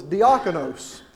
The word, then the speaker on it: diakonos: And if you were to pronounce that, or at least the best that I can pronounce it, that word is diakonos.